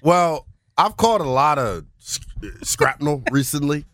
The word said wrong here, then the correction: shrapnel: Shrapnel, whatever the word is. It's early. How do you say it?